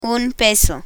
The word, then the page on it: un peso: Spanish